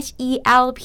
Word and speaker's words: help: h e l p มันมีทั้ง l แล้วก็ p ต้องอ่านว่า help เห็นไหม yeah, you have to hear both of them